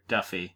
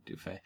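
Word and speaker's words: Duffey: I'm kind of interested in Tyler Dufay. Tyler Duffy. We'll get to that later. Do want to note that we do know how to say his name, but it is fun to say Dufay.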